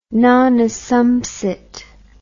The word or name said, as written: non assumpsit